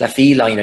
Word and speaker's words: La Feline: Is it La Feline or La Feline? – It's La Feline. I think it's the cat in French, Dave.